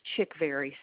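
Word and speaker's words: Chickvary: It's Chickberry, C-H-I-C-K-V, like in Victor, A-R-Y.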